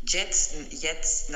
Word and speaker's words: jetzt: Jetzt tienes que no pronunciar tanto la J. Jetzt, jetzt, no, es like una J muy suave, es una J.